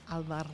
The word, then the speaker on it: Alvarado: How would I say it with the little Spanish flick, though? I know I'm not supposed to, but... – No, roll the R. – Roll it? – Alvarado.